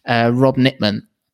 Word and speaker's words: Rob Nitman: Today we have got a strength and conditioning coach and a man of many talents, Rob Nitman. Say that right, didn't I? Yeah, you got it. Yeah. it's like knitting or like those horrible little things you get in your hair.